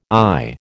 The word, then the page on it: I: American English